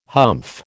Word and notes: humph: American English

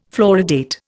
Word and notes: fluoridate: Indian

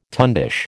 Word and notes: tundish: American English

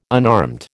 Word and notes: unarmed: American English

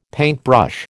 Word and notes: paintbrush: American English

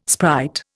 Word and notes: sprite: Indian

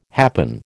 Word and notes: happen: American English

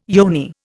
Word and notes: yoni: Indian

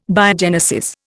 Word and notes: Biogenesis: Indian

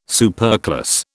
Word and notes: superclass: British English

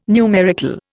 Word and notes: numerical: Indian

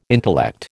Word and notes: intellect: American English

